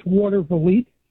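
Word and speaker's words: Watervliet: Let's go to Watervillay. I think that's how you pronounce it. New York, the great WGD. How do you pronounce that? – It's Watervillay. – I was close. – It's a Dutch word.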